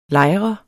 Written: lejr

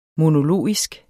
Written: monologisk

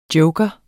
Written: joker

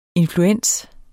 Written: influens

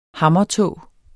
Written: hammertå

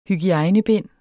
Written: hygiejnebind

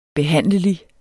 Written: behandlelig

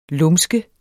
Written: lumske

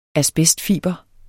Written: asbestfiber

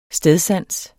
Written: stedsans

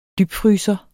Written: dybfryser

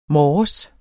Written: morges

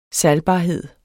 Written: salgbarhed

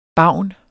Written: BAVNERNES

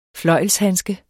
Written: fløjlshandske

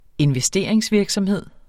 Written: investeringsvirksomhed

